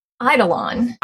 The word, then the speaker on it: eidolon: Eidolon. – Eidolon.